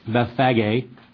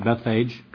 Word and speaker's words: Bethphage: It says, and when they drew near to Jerusalem and had come to Bethphage, or some pronounce it Bethphage,